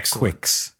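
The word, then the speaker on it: Quix: Croquis being French for just art, C-R-O-Q-U-I-S. You know, if you can picture Gal's Panic, but not Kicks or Kicks. Did we ever figure out how to pronounce that game? – Quicks. – Okay, not like Quicks. – People debate about it, but on the Japanese box art, it has the phonetics written out. So Quicks.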